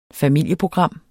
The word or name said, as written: familieprogram